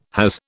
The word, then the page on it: has: British English